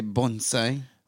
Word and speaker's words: bonsai: Can I just say the fact that you say bonsai? – Well, that's the way it's pronounced. Bonsai.